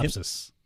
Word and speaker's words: Lapsus: Lapsus? Is that what it's pronounced? – Lapsus? Yeah. Lapsus.